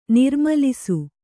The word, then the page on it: ನಿರ್ಮೂಲಿಸು: nirmūlisu